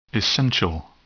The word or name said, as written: essential